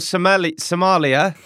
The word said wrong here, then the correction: sommelier: What is it? – Sommelier. – Williamsmore, Sommelier.